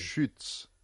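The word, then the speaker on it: Schütz: ¿Queréis que os haga la pronunciación ya fetén, auténtica, la irreversible? – A estas horas de la tarde. – Schutz. – O sea, hay que ver cómo lo produce. – S-C-H-U con umlaut, que es lo que llamáis vosotros diéresis.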